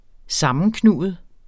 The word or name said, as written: sammenknuget